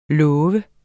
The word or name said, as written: lov